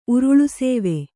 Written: ಉರುಳುಸೇವೆ

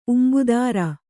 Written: ಉಂಬುದಾರ

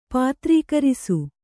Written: ಪಾತ್ರೀಕರಿಸು

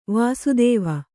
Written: ವಾಸ್ತವ

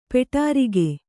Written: ಪೊಡರ್ಕೆ

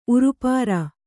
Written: ಉರುಪಾರ

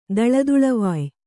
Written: ದಳದುಳವಾಯ್

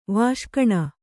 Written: ವಾಷ್ಕಣ

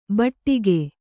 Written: ಬೆಡಗು

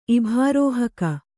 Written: ಇಭಾರೋಹಕ

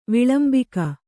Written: ವಿಳಂಬಿಕ

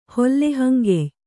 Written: ಹೊಲ್ಲೆಹಂಗೆಯ್

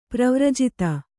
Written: ಪರಿವರ್ಜಿತ

